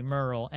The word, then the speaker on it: Merle: Marlene, who is played by, I just had it right over here, Meryl Dandridge. I hope I pronounced her first name correctly. Merle, Merle. She plays Marlene.